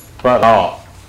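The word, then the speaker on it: apara: If you take all vidya, can be classified according to this passage into para, Upara, long A. Upara.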